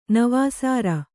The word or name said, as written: ನವಾಸಾರ